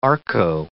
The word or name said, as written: arco